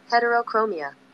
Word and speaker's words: heterochromia: Heterochromia.